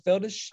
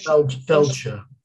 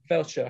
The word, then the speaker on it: Feldshuh: Yeah, Tova, how do you say it? Tova Feldish? – I think it's Feldshuh. – Feldshuh, okay.